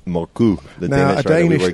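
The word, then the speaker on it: Mørkøv: Yeah, a Danish listener got in touch with the correct pronunciation. Morkov in English, or Morkov, but Moku apparently in Danish.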